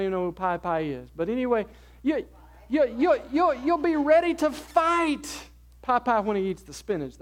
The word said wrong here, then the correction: Popeye: What did I say? Popeye. Is that better? Popeye.